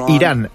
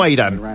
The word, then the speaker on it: Irán: Nuestro país se pronuncia Irán, no a Irán.